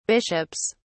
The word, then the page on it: bishops: British English